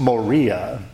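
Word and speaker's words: moria (μωρία): And the Greek word that Paul used there, it's pronounced moria, is based on, related to, even sounds like our English words moron and moronic.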